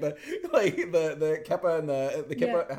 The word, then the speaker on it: kippah: How's it pronounced? I always get that one wrong. – Kippah. – That's because there's another word, K-E-P-I, which I think is pronounced kepi, which is the name of the hats from the Civil War.